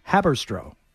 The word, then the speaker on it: Haberstroh: It's Tom Haberstroh. Am I pronouncing that correctly, Jim? Yes.